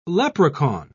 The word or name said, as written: leprechaun